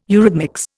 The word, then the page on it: eurhythmics: Indian